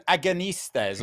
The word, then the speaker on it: Agonistes: I always think of it in terms of agnosticism because Nixon was such a polarizing figure in his day. But that's not correct. And thank you. Agonistes.